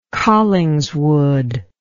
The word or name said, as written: collingswood